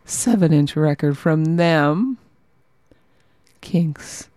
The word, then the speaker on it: Qinqs: Qinqs. I think is how you say it. Q I N Q S.